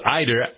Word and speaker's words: Ider: And let's see, about 6 miles east of there, in a town called Ider, I-D-E-R. Not, I'm not being a New Englander pronouncing I-D-A as I-D-E-R. Nope. It's Ider, Alabama.